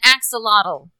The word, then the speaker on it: axolotl: An axolotl.